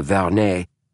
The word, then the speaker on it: Vernet: My grandmother on my mother's side was a Vernet, said Holmes, giving the name its proper French pronunciation.